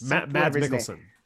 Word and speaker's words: Mads Mikkelsen: Yes, Mads Mikkelsen.